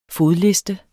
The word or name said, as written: fodliste